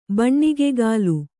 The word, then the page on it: ಬಣ್ಣಿಗೆಗಾಲು: baṇṇigegālu